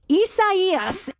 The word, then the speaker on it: Isaias: and I think it's pronounced Isaias. Is that right? – Isaias. – No, I didn't get it right. Say, what? Say it again for me. – Isaias. – All right. It's spelled I-S-A-I-A-S. Isaias.